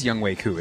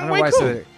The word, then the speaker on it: Younghoe Koo: And then Younghoe Koo. I'm not even going to say it. Koo. Keep the 58-yarder for the win. So. – It is Younghoe Koo, isn't it? – Yeah, Younghoe Koo.